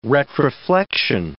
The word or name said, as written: retroflection